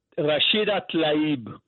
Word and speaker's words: Rashida Tlaib: Rashida Tlaib.